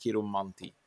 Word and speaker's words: kiromanti: kiromanti, eller vad uttalar man det? Eh, säger jag rätt? Kiromanti? – Ja, man kan väl säga kiromanti också, det spelar så stor roll.